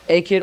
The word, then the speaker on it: Akkad: A-K-K-A-D? Akkad or Akkad? Either way you want to pronounce it.